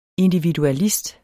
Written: individualist

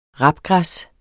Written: rapgræs

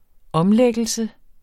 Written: omlæggelse

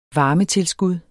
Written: varmetilskud

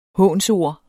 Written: hånsord

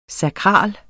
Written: sakral

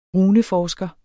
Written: runeforsker

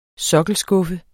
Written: sokkelskuffe

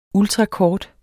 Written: ultrakort

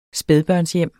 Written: spædbørnshjem

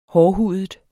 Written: hårdhudet

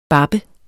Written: bappe

